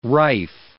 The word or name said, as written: rife